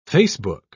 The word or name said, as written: facebook